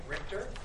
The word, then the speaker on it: Richter: First name, Alan, and I'll spell that, A-L-A-N. Nobody gets it right. Second name, Richter, R-I-C-H-T-E-R, and your address.